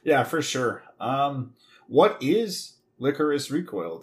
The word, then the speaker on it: Lycoris Recoil: I mean, I pronounce it Lycoris Recoil, mainly because I feel like it kind of looks and sounds like licorice, but that just might be a weird thing on my part.